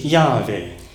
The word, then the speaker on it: Yahweh: In the Hebrew language, God's name is Yahweh.